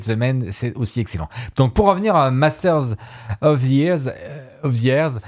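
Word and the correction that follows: Masters of the Air: Tu sais que l'accent que tu prononces, on dirait Master of the Oreille. Hein. – Oui, c'est... – Comment tu prononces? – Oui. Master of the Air.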